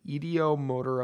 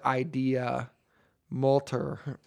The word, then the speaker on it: ideomotor: Ideo. Pronounced ideo. – Oh, ideo. – Yeah, oh, I've been fucking it up the whole night then. Sorry. – /mo/tor. Ideomotor effect. – Thank you for breaking down how to pronounce motor for me. – Well, then it says here you can also pronounce it idea motor effect.